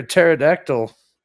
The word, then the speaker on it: pterodactyl: Why can't you hear a pterodactyl in the bathroom? – I don't know. Why can't you hear a pterodactyl? – Because the pee is silent.